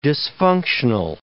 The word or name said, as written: dysfunctional